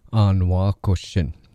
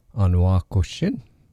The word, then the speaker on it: anwakoshin: And if you notice, both of them end in the sound shin. And the most notable part that I want you to pay attention to is there's an N sound at the end. Anwakoshin, anweishin, anwakoshin, anweishin.